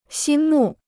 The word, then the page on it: 心目: xīn mù